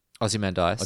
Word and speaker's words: Ozymandias: Ozymandias. – Ozymandias.